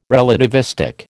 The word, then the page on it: relativistic: American English